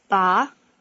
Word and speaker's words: 把: 黄 The counter for chairs is pronounced ba.